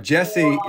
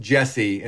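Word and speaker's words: Djesse: Jesse is pronounced Jesse, and it's a reference to his initials, J-C. The D is silent.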